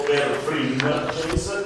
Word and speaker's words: perfringens: Già vi dico, la parola perfringens non è facile da pronunciare neanche da scrivere, ma sentire così, soprattutto perfringens con questa N prima della G, perché molte persone pronunciano e in alcuni testi si scrivono sbagliando perfringens, non si dice perfringens ma perfringens dal latino perfringens.